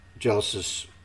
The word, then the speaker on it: Geillis: She can't save her, But what she's doing is she's talking to Gillis about what... I say Gillis because it's spelled G-E-I-L-L-I-S. Could be Gillis.